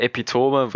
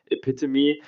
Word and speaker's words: Epitome: Also, wir sagen immer Epitome, weil wir halt, halt in Deutschland sind, da spricht man es halt Deutsch aus, aber die kommen halt aus Australien und deswegen heißt es Epitome